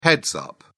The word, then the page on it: heads-up: British English